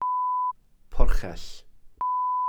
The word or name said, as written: porchell